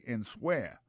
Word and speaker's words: answer: Ça sortit de verbe to answer, qui veut dire répondre, and so I écrit comme ça, A-N-S-W-E-R. Alors, sous ta, pour un temps pour prononcer, il y a tout mot là, on tape dit answer. C'est pas comme ça, on dit, c'est answer.